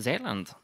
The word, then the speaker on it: Zeeland: Zeeland, actually, is how it's pronounced.